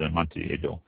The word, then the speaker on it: Amontillado: A aged Fino is often called an Amontillado. If I said that right, I don't think I did. Amontillado. Yeah, that's it, Amontillado, which I'll explain a little bit more in a minute here.